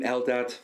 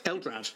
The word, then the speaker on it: Eldrad: I feel like with the crystals, they could have tied it into, and I'm going to get his name wrong, is his name Eldad? – Eldrad.